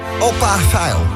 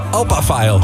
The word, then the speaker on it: Alphaville: Opa Veil. – Opa Veil. – Opa Veil. – Alphaville, ja, wat je wil.